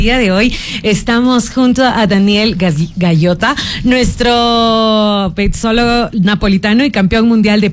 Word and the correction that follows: pizzaiolo: Pizzaiolo. – Pizzaiolo.